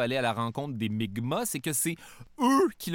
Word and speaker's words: Mi'kmaq: où Jacques Cartier et son équipage vont être accueillis par des membres de la nation Micmac, qui se prononce en fait Mi'kmaq, dans ce qui sera le premier contact.